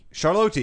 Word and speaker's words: Charlotte: They are in beautiful, I think it's pronounced Charlotte. – Charlotte, yes.